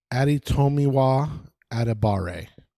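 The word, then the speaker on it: Adebawore: So I looked up the pronunciation. – Yeah, it's Adetomiwa Adebare.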